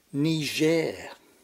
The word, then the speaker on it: Niger: And if you must mention that African country between Mali and Chad, be careful to pronounce it Niger, in supposed deference to its having once been a French colony.